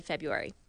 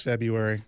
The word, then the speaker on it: February: It's February. – It's my favorite month of the year to hear people pronounce. – Right. – Because some people say February. Yeah. But there are people that say it February.